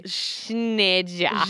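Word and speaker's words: Cneajna: Nezhna. of Moldavia. – Yeah, I'm sure the names in your thing are harder to pronounce than mine. – I think there's a silent C. It's C-N-E-A-J-N-A. Schneja.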